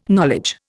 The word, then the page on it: knowledge: Indian